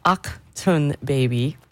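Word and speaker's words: Achtung Baby: Akhtun Baby. I was today years old when I realized it's pronounced that way because it's German.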